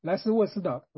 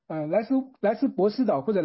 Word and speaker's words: 莱斯沃斯岛: 莱斯，等一下，我这里是不是应该在写到那个莱斯沃斯岛啊，莱斯沃斯岛，我刚才口误。 – OK。 – 莱斯博斯岛或者莱斯沃斯岛，因为在希腊语当中那个这个 B 发音是 V，所以是莱斯沃斯岛。